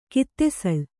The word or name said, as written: ಕುತ್ತೆಸಳು